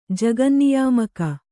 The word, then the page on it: ಜಗನ್ನಿಯಾಮಕ: kannada